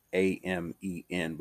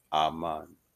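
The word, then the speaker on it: AHMAN (אָמֵן): Ahman, Amen. Not AMEN, but AHMAN, Aman, which means so be it.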